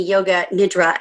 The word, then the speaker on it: yoga nidra: I came to you and you showed me yoga nidra, and please tell me if I said that correctly.